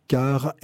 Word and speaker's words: KAAR: Car FM. Ça s'écrit K-A-A-R, mais phonétiquement, ce nom est tout à fait approprié pour s'associer à l'écoute en voiture.